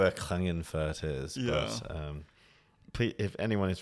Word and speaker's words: Klagenfurt: And it's from TEDx Klagenfurt.